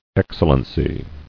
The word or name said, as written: Excellencies